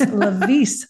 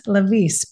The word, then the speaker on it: Lewies: who I was just informed says the correct pronunciation for those of us who want to be authentic is Lewies, Lewies, because you are originally from South Africa.